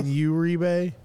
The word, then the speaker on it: Uribe: Did I butcher it by saying Uribe? – You said his name right.